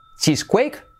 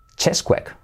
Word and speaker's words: Cheesequake: Yo hubiera dicho chisquake, como parece a cheesecake, como tarta. Chisacuake, chisquake. ¿Cómo pensáis que se dice? Os dejo un poquito de tiempo, va. ¿Alguien ha dado el pulsador? Sí, a ver, ¿cómo se dice? ¿Cheesequake? ¿Chacquake? ¡No! Se dice chesquake.